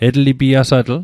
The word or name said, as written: etlipiasatl